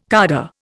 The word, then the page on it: cadre: Indian